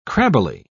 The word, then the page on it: crabbily: British English